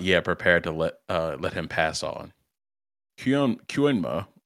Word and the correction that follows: Koenma: Koenma, there we go.